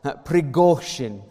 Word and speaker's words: Prigozhin: Prigozhin. Apparently the emphasis is on the go.